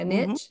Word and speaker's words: niche: Like, that is, it's kind of mind blowing to me, but I'm so excited because you obviously have like such a niche. Is that how you say it? A niche? – Mm-hmm. Yeah. I think it goes both ways.